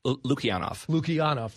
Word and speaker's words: Lukianoff: Greg Lukianoff. – Lukianoff. – Thank you very much. I missed somewhat of a syllable there. – It's spelled weird.